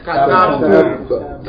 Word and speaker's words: kataba: Three syllables. Ka. Tab and two. Remember, the ta with fatha followed by ba with sukoon is one syllable. When a letter has sukoon, you join it to the letter before it, making one syllable.